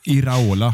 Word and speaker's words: Iraola: Eh, Ira, Iraola heter han va? – Korrekt.